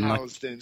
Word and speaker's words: Housden: Housden. Say it with a Z.